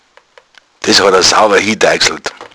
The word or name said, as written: deixln